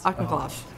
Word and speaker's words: Auchincloss: Auchincloss.